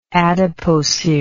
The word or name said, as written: adipocerous